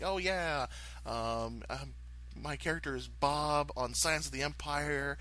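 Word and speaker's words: B'ahb: It's B apostrophe AHB, and it is pronounced Bob because the character was originally started just for me to have fun.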